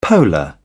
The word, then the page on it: polar: British English